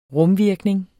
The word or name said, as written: rumvirkning